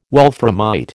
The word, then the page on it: wolframite: American English